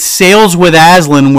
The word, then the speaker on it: Sales with Aslan: It's Sales with Aslan, where we emphasize the ales.